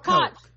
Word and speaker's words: Koch: C-K-O. Oh, is it Coke? Like Coke Brothers. Oh, K-O-C-H? Is that pronounced Coke Brother? Like Coke? – I'm not sure. – Ronald Koch. – Koch.